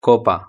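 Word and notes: copa: European Spanish

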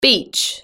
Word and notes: beach: British English

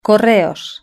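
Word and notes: correos: Spain

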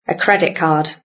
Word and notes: credit card: British English

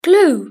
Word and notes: glue: British English

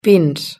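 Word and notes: pins: Spanish (Spain)

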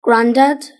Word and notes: granddad: British English

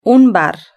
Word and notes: un bar: Spain Spanish

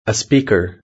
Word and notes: a speaker: British English

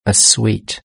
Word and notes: a sweet: British English

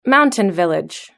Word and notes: mountain village: British English

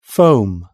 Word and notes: foam: British English

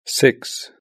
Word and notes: six: British English

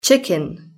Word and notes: chicken: British English

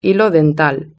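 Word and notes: hilo dental: Spain

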